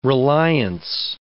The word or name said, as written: reliance